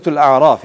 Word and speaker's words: Al-A'raf: The next one is Surah Al-A'raf. It rhymes with An'am, which is the seventh Surah of the Quran.